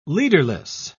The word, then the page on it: leaderless: British English